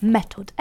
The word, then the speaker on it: Metod: Sorry for butchering the Swedish, but Method. M-E-T-O-D if you want to look it up yourself.